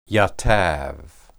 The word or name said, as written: yatab